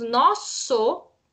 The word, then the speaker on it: nosso: Escrevemos nosso, mas falamos nosso.